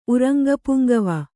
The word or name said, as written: ಉರಂಗಪುಂಗವ